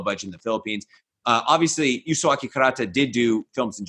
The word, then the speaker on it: Yasuaki Kurata: Yasuaki, right? – Yes, Yasuaki Kurata.